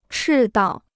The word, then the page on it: 赤道: chì dào